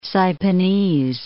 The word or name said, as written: Saipanese